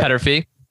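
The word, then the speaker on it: Peterffy: One unique thing about IBKR is, and I don't have the exact numbers in front of me, but the founder, and I think I'm pronouncing this right, Petterfee? You might know Petterfee. Yep. Petterfee.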